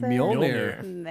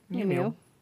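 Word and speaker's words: Mjölnir: Mjolnir. – There we go. Thank you. Mjolnir.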